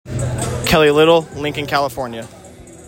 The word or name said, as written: Kelly Little